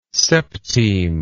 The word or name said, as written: septime